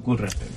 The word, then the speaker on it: Gujarat: Por ejemplo, es un niño de 14 años de Pakistán, de Gujarat. ¿Lo he dicho bien? – Gujarat. – Tú lo has dicho infinitamente mejor.